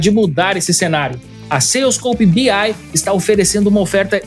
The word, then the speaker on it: Salescope: Eu vou deixar aqui o link também para você já anotar e conferir na sequência adm.to barra salescope. E o Salescope se escreve com S-A-L-E-S-C-O-P-E.